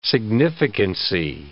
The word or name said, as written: significancy